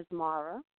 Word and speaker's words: Asmara: Hopefully I'm saying this right, because, girl, I could tear up a name. Welcome to the chat room. – Thank you for having me. And you are almost right. It's Jay Asmara. – Asmara, okay.